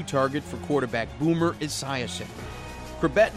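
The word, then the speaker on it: Chrebet: His last name, pronounced Krabet, became a household name after he made the 53-man roster out of training camp in 1995.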